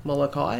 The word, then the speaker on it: Molokai: Molokai.